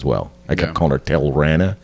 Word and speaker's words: Tilarna: One policeman, Kay Matoba's partner, is killed during a drug bust by a Samanian, leading to getting a new partner, Tilrana. That's how it's pronounced. It's spelled T-I-L-A-R-N-A, but Japanese, it's pronounced the R's. The L's spelled like pronounced like an R.